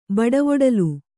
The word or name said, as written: ಬಡವೊಡಲ್